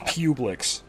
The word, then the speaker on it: Publix: And it's pronounced Publix.